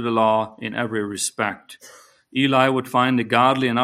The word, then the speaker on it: Heli: Joseph's father, um, the best way to say it is Eli, it's spelled H E L I, but it's pronounced similar to Eli, was a devout Jewish man, and Joseph followed in his footsteps of his father, very devout.